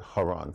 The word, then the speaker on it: Haran: It's C-H-A-R-A-N, the ch sound in Hebrew. In English, what they did, they dropped the C, so it's easier to pronounce. So you try to pronounce C-H-A-R-A-N, and most of you would try to say Charan, but they dropped the C. That's where we get Haran from.